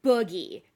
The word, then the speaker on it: Boogie: Note that it is Boogie. And for those of you who are my personal friends who are listening to this podcast, it is Boogie, B-O-O-G-I-E.